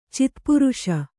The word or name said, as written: ಚಿತ್ಪುರುಷ